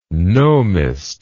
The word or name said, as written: gnomist